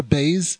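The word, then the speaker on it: beis (בית): The letter beis, when we pronounce it, is like the word bias.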